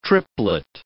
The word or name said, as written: triplet